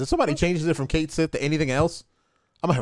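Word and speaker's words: Cait Sith: And which is another thing, you know, Kate Sith. And then years later, somebody's like, you mean Ketchy? What? You know, it's Welsh. It's Ketchy.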